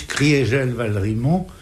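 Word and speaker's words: Kriegel-Valrimont: On prononce Maurice Criégel-Valrimont pour marquer que en 18, l'Alsace a cessé d'être germanisée.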